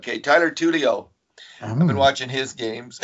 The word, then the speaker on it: Tullio: I've been watching some prospect games from a couple of Oiler forwards who were drafted, not Oiler forwards yet, but Tyler Tuoli of the Oshawa Generals, who I always mispronounce as something. Just did again. – Well, what is it? What is it? Is it not Tuoli? – Tulio. T-U-L-L-I-O.